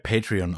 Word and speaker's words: Patreon: Also es wird Patreon ausgesprochen.